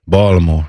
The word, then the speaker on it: Baltimore: So he and if you were talking from Matt, I'm not supposed to pronounce the T. Baltimore.